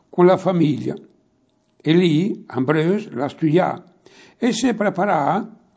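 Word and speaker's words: Ambrœus: Sant'Ambrose a sa scrivere senza l'accento fra Sant e l'Ambrose, e Ambrose è A-M-B-R-O-E-U-S, Ambrose, e inici a sa scrivere.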